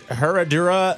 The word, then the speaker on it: Herradura: They have like the, I'm going to butcher the way you say this, but it's Herradura. – No, you said it right.